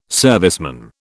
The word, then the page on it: serviceman: British English